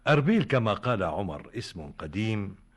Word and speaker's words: أربيل: ففي حوالي عام 2000 قبل الميلاد، في عهد السومريين، ورد اسمها أوربيلم، ثم حرف الاسم إلى أربيلم، وسماها الإغريق أربيلا، وسماها العرب أربل بدون ياء. أما اليوم فتنطق أربيل بإضافة الياء بعد الباء.